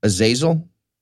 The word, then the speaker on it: Azazel: I think the name that you were saying before, it's pronounced Azazel. – Pretty sure it's Azazel.